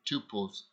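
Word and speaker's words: tupos (τύπος): That word pattern means tupos, or it's rather pronounced tupos in the Greek.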